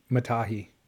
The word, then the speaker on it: Matahi: Matahi. – First a 9.3, then a 10. How do I say his name? – Matahi. – Sorry, you combined him and Manoa's name.